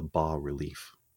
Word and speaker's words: bas-relief: So I was creating something that's called, it's called a bas-relief. That's how you pronounce it.